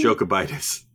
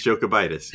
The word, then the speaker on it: Jokubaitis: Jokubaitis. – Jokubaitis? Is that – are we sure? – Rokas Jokubaitis, yeah.